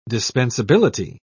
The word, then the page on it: dispensability: British English